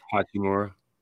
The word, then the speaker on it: Hachimura: Hachimura.